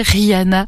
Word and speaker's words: Riana: Avant, on était avec Zazie et là, avec Rihanna, mais pas celle que vous croyez, Rihanna, qui nous vient de Suisse allemande. Ça s'écrit R-I-A-N-A et elle chante son Coffee Creme.